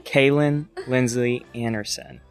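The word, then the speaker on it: Kalyn Lindsly Anderson: I am your host, here with Kalyn Lindsay of Basil's Food Trucks. Am I saying that right? – Yep. Well, Anderson now. Kalyn Lindsley Anderson. – Kalyn Lindsley Anderson.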